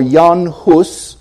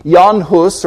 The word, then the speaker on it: Jan Hus: Well, John Huss, or Jan Hus, I don't speak Czech, so I'm not trying to make pretensions here, but that's what I've heard how it's pronounced. Jan Hus, or the English version, John Huss, was born in 1369.